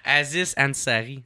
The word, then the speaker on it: Aziz Ansari: Aziz Ansari.